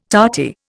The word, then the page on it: tarty: Indian